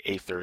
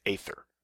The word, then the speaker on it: Aethyr: He also attempted to escape the zone, but was stopped and defeated by the might of Aether. I'm going to say Aether. It's hard to pronounce, but I'm going with Aether. A-E-T-H-Y-R.